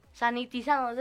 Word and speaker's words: sanitizado: Bueno, bueno, así que estamos aquí, brothers, no se preocupen, estamos aquí con la Susana a distancia, usamos todos cubrebocas, tú estás sanatizado, ahora sí lo dije bien, lo dije bien, ¿no? ¿No? Sanitizado, seguro que es sanitizado. Yo lo había escuchado como sanatizado en un noticiero.